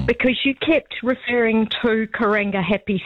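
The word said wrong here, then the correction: Karangahape: Station for the station on the CRL. – Yes. – The actual correct name is Karanga Ahapi.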